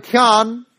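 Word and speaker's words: chen (חן): The word grace is in the Old Testament from the Hebrew word, see if I can pronounce it right, chen. It's spelt C-H-E-N.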